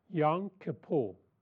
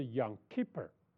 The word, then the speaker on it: Yom Kippur: 赎罪日啊，这个犹太人的发音叫做 Yom Kippur， Yom Kippur。那美国人的发音是 Yom Kippur， OK，那没无所谓啊，这叫赎罪日，是犹太人一年中最重要的圣日。